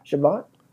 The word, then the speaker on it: Chabot: Is it Chabot or Chabot? How do you pronounce that? – Chabot.